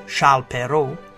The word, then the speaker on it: Charles Perrault: The birth of French author Charles Perrault, pronounced in French as Charles Perrault, is situated on the timeline in the 17th century in 1628.